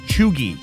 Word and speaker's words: cheugy: Well, it's spelled C-H-E-U-G-Y, and it's pronounced chuggy.